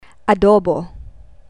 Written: adobo

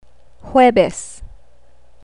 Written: huwebes

